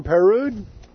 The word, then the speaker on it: Perrod: Pronounce your name Perud? – Sort of. Yeah, that's Swiss. – Oh, okay. Well, give me a better pronunciation. – Well, in French, we say Peru.